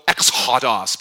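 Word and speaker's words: ex hodos (ἔξοδος): So if you want to say it correctly, you should go ex hodos, but that's weird, isn't it? So we just say Exodus.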